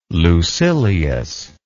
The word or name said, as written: Lucilius